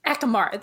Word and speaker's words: Akamara: Oh my God, I'm going to, Akamara, did I say that right? Tarot. I cannot pronounce things, guys. Please go easy on me. – Is it Akamara? – Akamara, there we go.